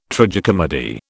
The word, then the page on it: tragicomedy: British English